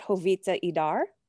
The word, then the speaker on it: Jovita Idar: And so I actively sought that out, and I found, and I correct me in my pronunciation, but Jovita Idar. – Yes. Perfect.